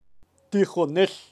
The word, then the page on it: tijonel: Kaqchikel